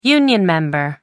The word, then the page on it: union member: American English